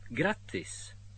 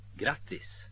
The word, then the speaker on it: grattis: Grattis. Grattis. – Вот здесь очень важно произнести гласную «а» коротко, иначе вместо «поздравляю» получится слово «бесплатно», которое пишется почти так же, только с одним «т», а не с двумя.